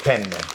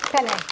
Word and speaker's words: penne: Penne. – Penne. I'm sorry, penne. Did I say it right the second time? – Yeah, I got it.